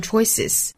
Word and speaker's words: choices: Limit your choices, 就可以把它读成说是 limit your choices, limit your choices, limit your choices. Choice 这个词呢变成复数，因为它尾部是一个的发音，变成复数之后呢需要加一个元音 a, choices, 之后再发 z, choices, choices, choices.